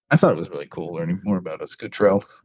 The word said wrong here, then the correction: Escutreth: I don't know how to say it. – Escutreth. – Escutreth. Thank you.